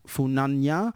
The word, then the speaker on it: Funana: Funana is I think it's actually pronounced Funanya.